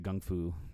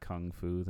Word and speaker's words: gung fu: In Mandarin, they pronounce it gung fu, not kung fu.